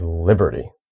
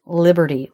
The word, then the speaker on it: liberty: So first of all, it's a three-syllable word and stress is on the first syllable. So that syllable should be a little bit longer. It's going to be a little higher in pitch. Liberty. So I'm going to say just the first syllable, li. And now I'm going to say the second two, pretty. Li, pretty. You can hear there's a definite difference in quality between the stressed and the unstressed.